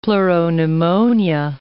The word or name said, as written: pleuropneumonia